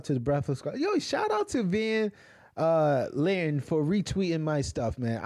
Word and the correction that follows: Van Lathan: Get his name right. Van Lathan. – Lathan. – All right. You said you said Lan.